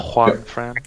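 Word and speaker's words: Juanfran: So, yeah I was looking at Juan Fran as well for Spain. Is that how you pronounce his name? – Yeah. – Juan Fran.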